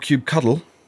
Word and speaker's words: kubectl: Is it not kubectl? – Well, what? Maybe in an American accent. How would you say it, Matt? – Kubectl? – Yeah, you sort of say cuddle. – Yeah, he says cuddle.